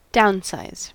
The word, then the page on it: downsize: American English